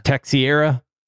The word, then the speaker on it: Teixeira: So this Jack Teixeira, is that how we're pronouncing that? – Yeah. – Teixeira,